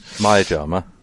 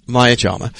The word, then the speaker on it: Maya Jama: Maya Jama. – Maya Jama.